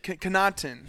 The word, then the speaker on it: Conotton: Am I saying that correctly? Con- Conotton. Thank you.